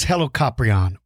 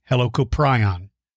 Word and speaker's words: Helicoprion: Helicoprion, Helicoprion. Either one of them is correct.